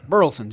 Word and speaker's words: Burleson: Burleson. Just kind of looks like it looks.